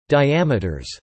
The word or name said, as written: diameters